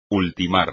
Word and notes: ultimar: espagnol

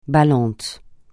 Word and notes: ballant: francais

